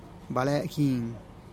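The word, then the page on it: bale gin: Teotitlán del Valle Zapotec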